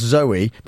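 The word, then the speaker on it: Zooey: And earlier on the programme, you will have heard the startling, headline-grabbing news that an actress called Zoe, pronounced Zoe, but with two O's, Deschanel, who you may have heard of, she's, I believe the word is kooky, has had her second baby.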